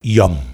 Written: Yawm

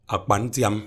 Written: akpantiang

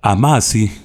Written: Amasi

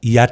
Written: Yat

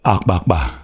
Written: Akpakpa

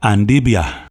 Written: Andibia

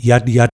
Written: Yatyat

